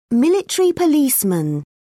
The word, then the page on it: military policeman: British English